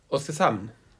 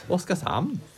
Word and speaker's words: Oskarshamn: Bra uttal på Oskarshamn. – Ja, men man måste alltid härma deras klack lite grann när man säger Oskarshamn. Exakt. – Oskarshamn.